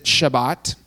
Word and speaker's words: Shabbat: And just to give you a little bit of background before we jump into the text today, Sabbath is if you were to go to Israel, they would pronounce it Shabbat. It's the same thing. So if you've heard that word before, that's the Hebrew pronunciation of the word that we use as Sabbath, because we mess up pronunciations all the time.